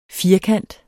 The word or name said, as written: FIRKANTERNES